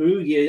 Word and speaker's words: Perugia: I can never pronounce that. – Perugia. AC Milan. Yeah. Perugia, that's it.